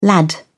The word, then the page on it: lad: British English